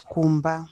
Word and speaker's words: kumba: Home in Shona is Kumba. I work far from home. Ndino shandira kure ne Kumba kwandino gara. – Kumba. Okay. Kumba.